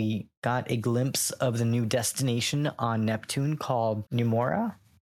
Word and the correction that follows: Neomuna: I hope I'm pronouncing it right. – What's it called? – But it is called Neomuna. There we go. – Neomuna.